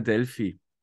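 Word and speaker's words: Delpy: Wird es Delphi ausgesprochen? – Ja. Weil es hat ja. kein PH. Ja, aber anscheinend sagt man Delphi.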